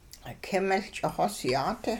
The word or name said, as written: k'ing-miL ch'ixosyaat